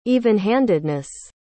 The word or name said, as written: evenhandedness